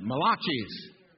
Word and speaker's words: Malachi: to the book of Malachi. Anybody heard of Malachi? Well, that sounds like a pizzeria, doesn't it? Malachi's. Malachi is the right way to pronounce that book.